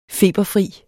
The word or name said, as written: feberfri